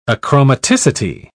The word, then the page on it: achromaticity: British English